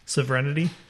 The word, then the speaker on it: sovereignty: How do you say that word? I'm bad at saying that word. – Sovereignty. – Sovereignty, really? – Yeah. The sovereign.